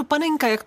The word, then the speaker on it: Panenka: Opravdu Panenka, jak to vyslovuju, a je to podle našeho Antonína Panenky, protože ten tak okouzel Španělsko, že vlastně po něm nazvali fotbalový časopis.